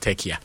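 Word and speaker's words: Türkiye: You mean Tekai? – Yes. In fact, they say they pronounce it Tekia. It is Turkey that we know.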